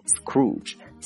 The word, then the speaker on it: Scrooge: 但是呢，斯克鲁奇这个名字 S-C-R-O-O-G-E 这个词啊，它就是一个表示吝啬鬼的意思啊，读成 Scrooge OK S-C-R-O-O-G-E Scrooge 就是名词吝啬鬼，或者叫做守财奴的意思。 OK，我们来看一个例句。 If you call someone a Scrooge, you disapprove them because they are very mean and hate spending money.